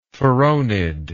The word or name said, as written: phoronid